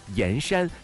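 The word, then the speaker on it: 铅山: 今天呢要带大家解锁一个超级冷门但是颜值非常高的小城啊， 90% 的人呢，看到他的名字的时候都会读错，因为他是一个多音字，他就是江西上人的盐山，这个盐呢，它就是铅笔的铅，但是这个字呢，读跟盐山在一起读地名的时候读盐，同时读姓的。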